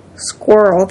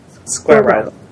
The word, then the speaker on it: squirrel: He pronounced it the American way, squirrel. The British way is to break it into two syllables. – Squirrel.